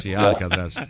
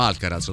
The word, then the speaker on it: Alcaraz: No, io scusate, ho sbagliato la pronuncia, l'ho chiamato Alcatraz, sì, fuga da Alcaraz. – Alcaraz. Lui c'aveva Clint Eastwood in testa. – Sì, fuga da Alcatraz. No, Alcaraz, ho sbagliato.